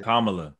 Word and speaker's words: Kamala: I believe that's how you say her name, correct? – Kamala. Uh, Kamala.